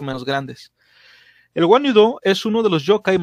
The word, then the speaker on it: Wanyudo: Eh, se escribe W-A-N-Y-U-D-O.